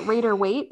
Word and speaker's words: Rider-Waite: I use the Rider-Waite.